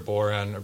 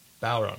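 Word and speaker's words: bodhrán: And you get a huge groove going too once it's good, especially if there's guitar players or a boron, or sorry, Balron, I think it's pronounced, the Irish drum.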